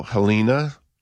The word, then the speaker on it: Jelena: And then witch hazel, Helena, or Jelena. Mm-hmm. It's spelled Jelena, but I... – it is. I think you're right in pronouncing it that way.